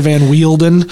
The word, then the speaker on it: Van Weelden: And, you know, it's not spelled like it's pronounced, so... – Close enough. – Well, Mr. Van Weelden.